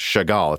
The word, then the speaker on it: Chagall: In summary, Zen 3 Threadripper Chagall is expected, or I guess it's actually pronounced Chagall, it's a French word, is expected to launch by November 2021.